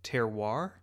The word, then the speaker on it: terroir: And he's on a farm and he's with this wheat farmer who is talking about terroir. – Yep. – Is that how you say it? – Yep.